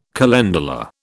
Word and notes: calendula: British English

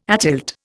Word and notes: atilt: Indian

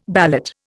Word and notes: ballot: Indian

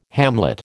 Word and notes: hamlet: American English

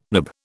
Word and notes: nib: British English

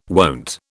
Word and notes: wont: British English

